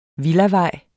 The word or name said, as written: villavej